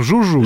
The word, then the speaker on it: Жужуй: Это ну, правда, некоторые по-другому иногда эту провинцию читают. – И мы не будем ее произносить, да. – Она созвучна... – Мы ее называем Жужуй.